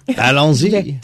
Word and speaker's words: Allons-y: Allons-y.